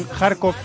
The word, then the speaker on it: Kharkiv: Se cruzan en Kharkov, que me ha dicho Pedro Martín, que es así como se lee Kharkov. Sí, porque se escribe Kharkiv, ¿no?